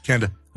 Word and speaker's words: Canada: There's three syllables in that, not one drawn out one. – Canada.